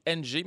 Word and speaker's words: Ng: Et parlant de Ligue nationale, au baseball majeur, vendredi, on a appelé que Kim Ng, ça s'écrit NG, mais ça se prononce Ng.